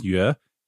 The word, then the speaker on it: Yue: My last name is Yue, and that U-E sound, no one can pronounce it unless you know the Chinese word, right?